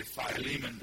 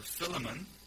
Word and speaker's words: Philemon: And if we say Philemon, they won't understand. So here it is. It's Philemon with Paul and Timotheos, Timothy. It's the Paul's letter to Philemon, a Colossian believer.